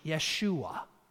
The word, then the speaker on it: Yeshua: They heard Joshua. Yeshua is the Hebrew pronunciation of his name.